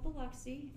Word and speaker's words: Biloxi: Like, I was like, how do you even pronounce, is it? – Biloxi. – Biloxi? Like, what is this? And Sergeant Tingle was like, it's called Biloxi, and it's actually not bad.